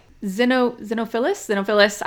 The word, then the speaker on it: Xenophilius: You mentioned it a little bit, but so whenever they are reading, whenever Hermione is reading about the Deathly Hallows, basically Xenophilus. I literally Googled that, so I pronounced that correctly. I was like, how do I pronounce his name? So it's spelled like X-E-N-O.